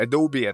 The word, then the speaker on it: Adobe: Да, Adobe, это правильно говорить.